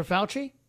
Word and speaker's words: Fauci: Oh, Dr. Fauci? – Yeah, Dr. Fauci.